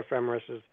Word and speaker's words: Ephemeris: It's spelled E-P-H-E-M-E-R-I-S, Ephemeris.